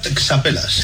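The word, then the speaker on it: txapelas: Sí, sí, se pronuncia texapelas. – Texapelas. Es que lo habían puesto fonéticamente, pero sí, escrito es texapelas, o sea, que son las boinas.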